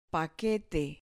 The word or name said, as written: paquete